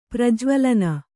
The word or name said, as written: ಪ್ರಜ್ವಲನ